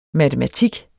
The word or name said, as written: matematik